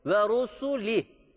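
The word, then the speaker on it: ve rusuli (رسله): Bu harfin harekesi ötredir. Ve ru, ve rusu, ve rusuli denmesi gerekiyor.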